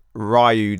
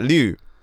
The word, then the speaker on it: Ryu: Like, no, Liu, is that how they pronounce it? – Yeah, kind of like, because they don't really pronounce the R's. The R's are kind of pronounced a bit like L's, so they say it quick, like Liu.